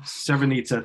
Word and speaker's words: Srebrenica: And then looking in particular at some of the things that happened with the United Nations and the genocides in Rwanda and I never pronounce it right. – Srebrenica, thank you.